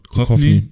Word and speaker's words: Duchovny: Ich habe äh, California Cation auf die vier gesetzt mit ähm, David Duchovny, wird der gleich ausgesprochen? Ja. Duchovny, Duchovny, ja.